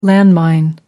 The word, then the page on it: landmine: American English